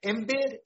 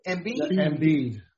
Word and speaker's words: Embiid: Embiid? – Embiid. – Embiid, that's what I'm saying, Embiid.